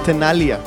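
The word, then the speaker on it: Tenaglia: במקור קטע מ-1998 של סלאדה יחד עם הדי-ג'י והפרודוסר דני טנאליה, תכלס, לא מבטאים את הג'י בשם שלו.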